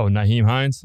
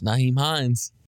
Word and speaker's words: Nyheim Hines: Oh, Nyheim Hines? – Nyheim Hines.